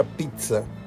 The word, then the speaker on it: Pizza: Cuando tú visualizas poner un negocio como el que actualmente tienes, que es la pizzería Luca Pizza o Luca Pizza, porque dos Zetas se pronuncian como T en italiano.